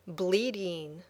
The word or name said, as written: bleeding